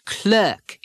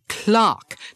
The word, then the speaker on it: clerk: The word clerk is not clerk in England. It's clerk, just like the family name, the clerk at the store.